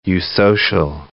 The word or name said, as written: eusocial